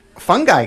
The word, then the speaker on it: Fungi: How do you say it, Nardwuar? – I say it the Fungi Girls.